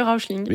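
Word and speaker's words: Räuschling: Je ne sais pas au niveau de la prononciation, si je serais juste, le Rauschling. – Oui, c'est ça, le Rauschling.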